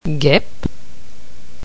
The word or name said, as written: guepe